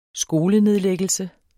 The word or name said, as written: skolenedlæggelse